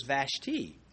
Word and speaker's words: Vashti: But the pronunciation of Vashti, well, if you one prefers Vashti, another prefers Vashti. Well, I drink tea. So, and I wear ties. I can go with either one, but I prefer pronouncing it Vashti, and you should too.